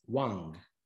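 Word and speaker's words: Wang (王): This is expressed in the Chinese character for king that you see pictured and that is pronounced as Wang.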